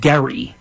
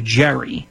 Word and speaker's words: Gerry: I mean, if you know about where gerrymandering came from, there was a governor by the name of Eldridge, and he pronounced it, I believe, Gerry, G-E-R-R-Y, but it turned into Jerry,